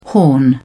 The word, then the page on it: horn: British English